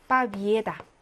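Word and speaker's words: 빠비에다 (победа): 명사 빠비에다. 이 승리라는 명사는 여러분 빠비에다하고 얘의 강세가 있고요. 승리하다 동사는 빠비지찌하고 뒤에 강세가 있어서 여기는 이 발음이라고 여기는 얘 발음이 나는 겁니다.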